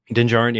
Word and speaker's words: Din Djarin: Did I say that right? – Din Djarin. – Yeah, the D is silent. No double D's.